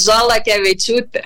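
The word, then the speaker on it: Šalakevičius: Perfecto, solo que aquí se escriben pajaritos, tenemos pajaritos, entonces Salakevicius.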